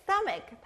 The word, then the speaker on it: stomach: 그럼 배, stomach 다시 발음해 볼게요. – 크크 stomach. 크크 stomach. 크크 stomach. – Excellent. Logan, 이 단어도 크 소리가 나는데요.